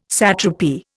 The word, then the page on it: satrapy: Indian